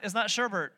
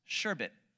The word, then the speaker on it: sherbet: It's not sherbert. There's no R at the end of it. The way you say it is sherbet.